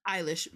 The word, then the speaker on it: Eilish: Am I saying her name right? Eilish? – Eilish. Billie Eilish.